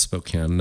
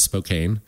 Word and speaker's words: Spokane: I'm like, do you know how many tens of thousands, maybe even hundreds of thousands of people know how to pronounce Spokane now correctly yeah. instead of Spokane? – Yeah.